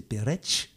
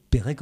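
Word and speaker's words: Perec: Et il faudrait prononcer Peretz. Bon, mais Pérec en français, ça passe très bien.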